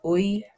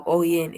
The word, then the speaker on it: Oyin: My actual name is Oyin, but it's spelled O-Y-I-N. But then the N is silent. So it's instead of Oyin, it's Oyin.